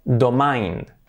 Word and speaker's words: domain: Domain, vamos a pensar en palabras que tengan esa misma estructura, sobre todo el final. Fijaos, domain se pronunciaría domain. Domain. ¿Vale? Domain. Domain. ¿Qué tenemos? Pues palabras como brain, cerebro, o también como chain, cadena. Por lo tanto, brain, chain, domain.